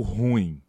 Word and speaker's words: ruim: Esse vocábulo, nessa palavra, ela é uma palavra oxítona. O que, que isso significa? Que significa que a última sílaba dela é que é a sílaba tônica. Então, o correto é ruim. Ruim.